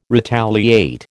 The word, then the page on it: retaliate: American English